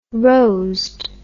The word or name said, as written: rosed